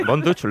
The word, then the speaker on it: Von Dutch: Así que, Von Dutch, ¿lo he pronunciado bien? – Sí, muy bien.